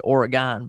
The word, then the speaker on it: Oregon: Being from Portland, and we pronounce it Oregon, by the way, not.